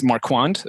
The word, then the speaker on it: Marquand: It's Marquand.